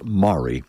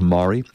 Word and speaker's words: Mairi: Just now, the beautiful fiddle played by Mary Campbell, or often pronounced Mari. Her name is spelled M-A-I-R-I. And I understand Scots often pronounce that as Mari.